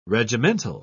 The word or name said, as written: regimental